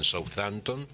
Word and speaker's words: Southampton: Los restos estaban en Southampton, Inglaterra.